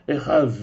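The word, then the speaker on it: эхаз (אחד): Без дагеша, так как она записана в нашем стихе, она произносилась ближе к звуку «ж», «з». И поэтому правильное прочтение в древнем иврите «Адунай эхаз».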